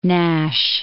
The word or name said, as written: Nash